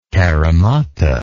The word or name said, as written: carromata